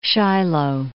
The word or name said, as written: Shiloh